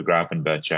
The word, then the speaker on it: Gravenberch: But yeah, if they both start, I'd rather take the cheaper Gravenberch, I would say. – That's a tremendous bit of pronunciation, by the way. I don't think anyone's been on this podcast and got his name right.